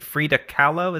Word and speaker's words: Kahlo: this uh, post secret is on a postcard of, of some artwork by I think Frida Kahlo, is that how you say that? Frida That's right. Did I pronounce the last right. name right? Sure.